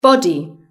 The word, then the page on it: body: British English